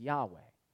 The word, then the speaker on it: Yahweh: And that is translated out of this this word that is written in English, YHWH. And so we pronounce it in kind of English. The English translation of it is Yahweh.